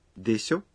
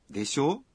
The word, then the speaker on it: でしょ: でしょ? – Là đúng không? Đây là cách nói thân mật. Các bạn lưu ý phát âm ngắn hơn. – でしょ?